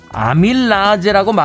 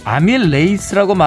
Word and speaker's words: 아밀레이스: 아밀라제라고 말하면 아재, 아밀레이스라고 말하면 요즘 애들이란 겁니다.